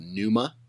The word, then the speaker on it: Pnuma: having back orders was that new company called Numa. Did you hear about those guys at all? – No, I haven't heard about them. – It's spelled P-N-U-M-A.